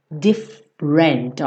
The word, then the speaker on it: different: Different. Olha só que interessante, tá? Different. Então você não fala different. Esse é do different não é pronunciado.